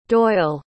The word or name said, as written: doyle